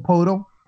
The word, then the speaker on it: Poeltl: Like, is it Poeltl? I think that's how you pronounce Poeltl? – It. – Jakob Poeltl.